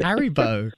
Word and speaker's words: Haribo: Haribo.